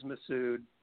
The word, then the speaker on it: Massoud: From what you just said, proper pronunciation is Massoud.